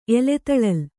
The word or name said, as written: ಎಲೆತಳಲ್